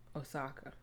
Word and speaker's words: Osaka: So let me, this is again talking about the bonnets, and it was also talking about Naomi Osaka.